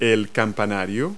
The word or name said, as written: steeple